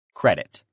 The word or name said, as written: credit